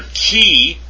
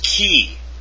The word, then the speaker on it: chi: In ancient Greek, keep on reading, the letter chi was probably pronounced like the CH in Scottish. Lach or German Bach. Since this sound does not occur in the English, the chi sound in chemist may be used instead.